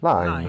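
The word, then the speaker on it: Lyne: Si tratta di Deep Water, il ritorno alla regia di Adrian Line o Lin, come lo pronunciate voi? – Line. – Line ok.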